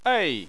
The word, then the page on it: ei: 4